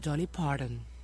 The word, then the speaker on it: Dolly Parton: No, señor, Dolly Parton, y usted sabe.